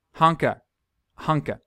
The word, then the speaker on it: Haneke: Haneke.